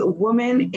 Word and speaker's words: WOMXN: And now when it comes on to Clubhouse, my favorite room to go in is Woman in Business. It's spelled WOMXN in business.